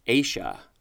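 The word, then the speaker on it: -aceae: So the word is pronounced Asia.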